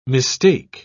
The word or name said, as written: mistake